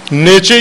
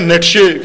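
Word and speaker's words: Nietzsche: اسی طرح نیچے یا نیٹشے کے دونوں طرح سے تلفظ کرتے ہیں ہم اس کا.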